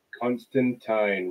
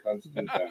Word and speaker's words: Constantine: is it Constantine or Constantine? – Mm. – Constantine rhymes with swine.